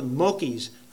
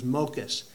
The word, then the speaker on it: mokas: I've called it before the mokies. I mean, for some reason that rings with me, but I think the proper pronunciation is mokas.